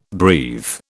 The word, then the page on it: breathe: British English